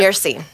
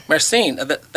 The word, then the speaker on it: myrcene: Myrcene. – Myrcene.